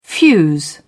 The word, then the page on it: fuse: British English